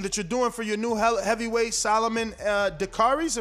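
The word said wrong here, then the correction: Dacres: Am I pronouncing that correct? – Dakers. – Oh, my God. We were killing that all morning. Dakers.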